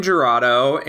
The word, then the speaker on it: Jurado: Damien, how do you say his last name? Giruda? – Damien Jurado and Jurado.